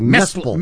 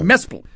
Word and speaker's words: Mesple: The mind I'm talking about is that of David, I don't know how to pronounce it, M-E-S-P-L-E, Mespel. Mespel.